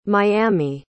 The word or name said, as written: miami